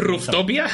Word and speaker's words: Rooftopia: Eh, Rooftopia o Rooftopia, me gusta cómo lo pronuncias Rooftopia. Rooftopia. Sí, para la sepa R O O F T O P I A, no busques Rooftopia porque no vas a encontrar Sería básicamente como la eh, Azotapia.